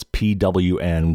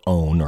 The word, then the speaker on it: pwn: That's PWN, it's pronounced pwn in the hacker community, or own, or if somebody's pwned, that means that they have been taken over.